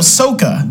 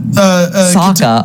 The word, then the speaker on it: Sokka: Ahsoka, was that right? – The what even character are you going for? Ahsoka's from Star Wars. – No, Sokka. – Sokka. Sokka. Katara and Sokka.